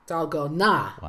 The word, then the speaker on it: Dalgona: It's the D is sort of pronounced as a T, and the G sometimes sounds sort of like a K, so it's Dalgona. Dalgona.